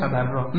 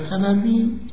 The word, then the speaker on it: تبری: اول کلمه تولی که ما گاهی این رو تولا میگیم و غلط به پارسی تلفظ میکنیم، نقطه مقابل اون تبری است. تولی، تبری، گاهی ما این رو پارسیش میکنیم، میگیم تولا، تبرا. نه، تولی و تبری قرائت صحیح کلمه است.